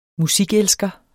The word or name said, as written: musikelsker